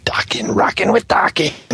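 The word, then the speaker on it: Daken: Apparently, he's European, where A is pronounced Ah, Dakin. – Only people could see you when you say that. – Dakin. – Nine Dokken.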